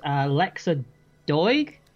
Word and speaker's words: Lexa Doig: The film stars Lexa Doig.